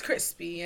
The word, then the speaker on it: Chrispy: D-A-B-A-N-D. My name is Cupcake. – And my name is Crispy. And that's spelled C-H-R-I-S-P-Y.